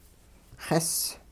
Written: xis

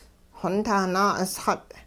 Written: xontah naa'usxut

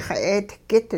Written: xa'eetgit

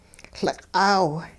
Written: Liq'aaw

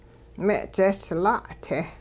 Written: me'dzehsla'-te

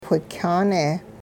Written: whikyaane

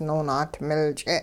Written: noonaatimil-ch'e

